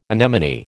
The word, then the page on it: anemone: American English